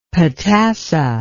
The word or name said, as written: potassa